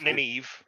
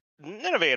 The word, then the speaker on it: Nineveh: So this, yeah, Wheel of Time, you know, so again, the casting, Nineveh, it's Nineveh, by the way. It's, if you look it up, that's how it's pronounced. – Oh my God, yeah. – Not Nineveh.